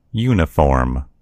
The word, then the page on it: uniform: American English